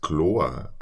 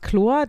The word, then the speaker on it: Kloor: Also es gibt wohl irgendwie, der ist nicht ganz chlor, dann ist der ja nicht ganz klar im Kopf, also eher von klar. – Aber das ist ja auch, also das ist ja eigentlich auch eine bayerisch anmutende Aussprache dann, finde ich. – Wobei man schreibt es K-L-O-O-R. – O-O-R, ah, okay.